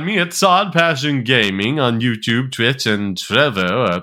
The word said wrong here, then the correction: Trovo: or however you pronounce the site's name. – Trovo. – Trovo, okay. – It's like Trove, but with an O at the end instead of an E. Trove, Trovo.